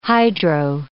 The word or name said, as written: hydro